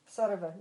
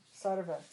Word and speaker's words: sarva: Is Sarva a long A? – Sarva, no, it's short. Sarva, Sarva Deva, Deva Namaskaraha.